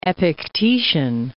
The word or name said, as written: Epictetian